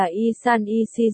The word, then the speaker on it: 1314: 1314 đọc là Y San Y Si giống với Y Sâng Y Si.